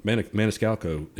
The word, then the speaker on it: Maniscalco: Maniscalco is.